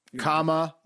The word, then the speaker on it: Kamala: Kamala. – Yes. – That's how you say it. – It's almost like common law.